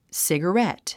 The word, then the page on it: cigarette: sìgərét; スィガレェット